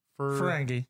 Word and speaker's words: Ferengi: Ferengi.